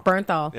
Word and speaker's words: Bernthal: No, because we've seen all these pictures with I'm gonna butcher his name, John Blumenthal. Did I say Berthel. – It Bernthal.